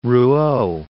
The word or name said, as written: Rouault